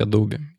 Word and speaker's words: Adobe: Однажды ребята из Adobe сказали, что правильно говорить Adobe, и с тех пор мир мой изменился.